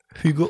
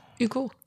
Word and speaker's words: Hugo: Hugo Owen. – Ohne das H. – Wie die schreiben das? – Hugo. – Hugo, bei Französisch.